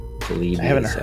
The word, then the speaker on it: Polybius: Polybius, absolutely.